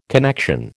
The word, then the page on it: connection: American English